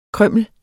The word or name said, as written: krymmel